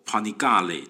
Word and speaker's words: Panigale: 好，那比方说呢，杜卡迪的这个当家的跑车系列，他们叫做 Panigale，就是呃意大利文的发音啊，但是估计呢，很多人是念不出来的，特别是拥有这些车款的车主。